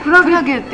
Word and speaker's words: 海月: 本日は 9 月 1 日から 9 月 30 日まで空の青、これは海月展、海月展、それをクラゲって読むんですよ。海の月と書いてクラゲって読むんですよ。